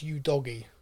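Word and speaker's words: Udogie: Scarlett, Divine, Udogi, which, by the way, I've been pronouncing wrong. It is Udogi. – Udogi! – I thought it was Udogi. It's not. It's Udogi.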